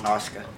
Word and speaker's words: Asuka: Asuka. – Asuka. – Yeah. – It's spelled like Asuka. – Yeah. But it's, and Asuka. It's like Shinsuke. It's Shinsuke.